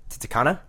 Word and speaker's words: Titicaca: Okay, don't pretend that you don't know how to pronounce this word. – I really hope it's not what I think it is. – Every 12-year-old boy knows how to pronounce this Titicaca. – Lake. There we go. – I was really hoping it's something else. – It's not. – Titicaca. – It's Lake Titicaca.